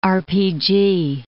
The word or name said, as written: RPG